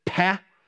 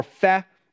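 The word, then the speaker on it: pe: And this is where we begin to see here this peh, or the P sometimes pronounced an F. So it's either peh or feh. Either way, it's okay. It's pronounced differently depending on the words, but just so you know that that's the next letter.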